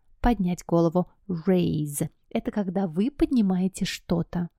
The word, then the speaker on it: raise: Raise one's hat. – Поднять голову. Обращаем внимание на произношение глагольчика «поднимать». Это не rise, это подниматься, а raise.